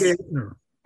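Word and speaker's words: Geithner: Gaetner. – Pardon? – Gaetner. – Yes. Gaetner.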